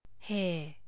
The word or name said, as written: hair